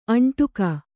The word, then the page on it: ಅಂಟಿಕ್ಕು: kannada; aṇṭikku